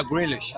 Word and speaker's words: Grealish: Vamos lá, panturrilha esquerda do Grelish. É isso? Falei certo? É, o é Grealish.